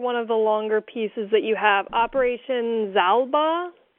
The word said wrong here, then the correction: Xibalba: Zibal, the, the X is, is G. It's Xibalba. It's a, a Mayan name.